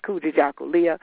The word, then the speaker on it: Kujichagulia: If any of you read it and were wondering how do you say it, it's Kujichagulia, and it is a Swahili word, a Kiswahili word meaning self-determination.